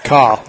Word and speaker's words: Coll: So, nice catch by Alex, and we spell it C-O-L-L, right? – Call. – Yeah. It is then Alex Call.